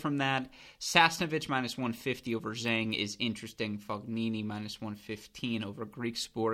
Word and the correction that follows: Fognini: Excuse me, Fognini. I apologize for the American pronunciation there.